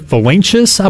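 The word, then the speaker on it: Valincius: But Tomas, who won the Ferris Trophy, which goes out to the best college baseball player, he pronounces it Valanchis, I believe.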